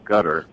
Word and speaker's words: Qatar: There's a country in the Middle East that people pronounce it out there, gutter, but it's spelled Q-A-T-A-R.